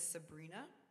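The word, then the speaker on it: Sabryna: So my name on Instagram is Sabrina, but it's spelled a little bit interestingly.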